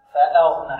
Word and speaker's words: فأغنى: هذا خطأ يقع في كل من الطلاب، وهو أن الهمزة محققة فيأتي بها مسهلة. هي صحيح الهمزة النظر بها كالمتهور وهي صعوبة في النطق، فيقول فأغنى عائلا فأغنى. لا، فأغنى. لا صحيح لا نلكز الهمزة لا نلكزها نقول فأغنى لا نأتي بها سهلة فأغنى نعم ووجدك عائلا